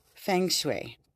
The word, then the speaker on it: feng shui: Feng shui, pronounced feng shui, is the ancient Chinese art of balancing the elements in one space for optimal harmony and energy flow.